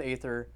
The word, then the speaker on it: aether: It says attune with aether, I guess it's now pronounced right because the A and the E aren't connected.